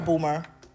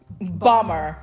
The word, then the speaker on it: Bomer: Bomer or Boomer? Bomer. – I think it's Bomer. You're right.